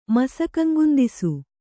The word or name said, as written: ಮಸಕಂಗುಂದಿಸು